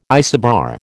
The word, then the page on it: isobar: American English